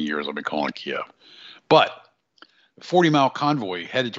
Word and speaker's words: Kyiv: If you're wondering why the 40-mile convoy headed towards Kyiv, which I will pronounce it Kyiv now,